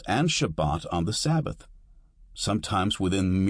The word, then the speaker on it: Shabbat: An example is Shabbat, Sabbath, with the accent on the second syllable.